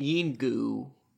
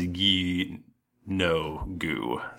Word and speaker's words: Yeenoghu: Someone in the chat room said, I always pronounced it Yingu. – Yingu. – G-O-O. I'm more focusing on the always part, not the I've done this once. – There was a pronunciation thing in the, it says ye no goo, so.